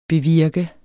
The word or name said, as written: bevirke